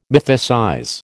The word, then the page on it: mythicize: American English